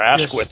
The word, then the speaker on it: Asquith: I was going to move to the prime minister, and I am not an H.H. Asquith, or Asquith, yes, as the way it's pronounced.